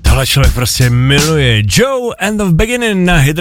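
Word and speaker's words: Djo: Jsem si říkal, proč si nechal takovéhle jméno, ono je vlastně srandovní, když se to píše D-J-O a čte se to vlastně jako Joe,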